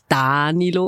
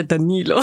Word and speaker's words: Danilo: Danilo oder Danilo? Hm. – Ich heiße Danilo Campisi. Auf Italienisch ist es so, man betont meistens die vorletzte Silbe, deswegen mhm.